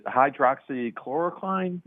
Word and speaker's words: hydroxychloroquine: One of the other controversies I'd like to ask our guests about is around the is it hydroxychloroquine? Am I saying that right? – Yeah.